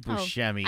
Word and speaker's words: Buscemi: It's Steve Buscemi.